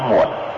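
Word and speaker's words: muộn (悶): Cho nên cái chữ mà môn cộng với chữ tâm đó, người Trung Hoa họ đọc là chữ muộn, tức là lo âu, buồn rầu.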